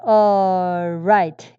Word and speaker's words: all right: all 발음을 어처럼 그냥 하세요. l 발음 하지 마시고. 그래서 everything was alright 이렇게 우리가 한국말로 하듯이 이렇게.